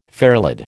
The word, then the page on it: fairlead: American English